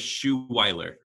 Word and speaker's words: Schuveiller: That's like the French version, I believe. So we'll go with Schuweiler.